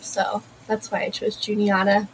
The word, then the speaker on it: Juniata: I don't know why I keep Juniata. Is that how you pronounce the name? – Yeah.